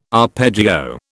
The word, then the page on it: arpeggio: British English